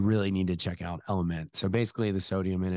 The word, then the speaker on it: LMNT: It's spelled L-M-N-T, pronounced Element, but it is an incredible recovery drink.